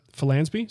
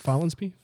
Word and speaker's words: Follansbee: And they have some specific recommendations, just a couple of people, Kelly Mailer, Michael Dunbar, Roy Underhill, Peter Follansbee. Is that pronounced properly? – Follansbee? – I think that's right.